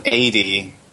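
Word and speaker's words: Eidy: So AD, there's this guy or this group, this duo, I think, musicians called AD, and they have written and composed the opening song of Delta. – AD, is that just spelled A-D? – No, it's E-I-D-Y, pronounced A-D.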